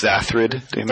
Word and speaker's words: Xathrid Demon: And then you use Xanthar Demon. – Why does everybody pronounce that card wrong? – Oh, it's Xathrid Demon. – There is no N in it. Just FYI.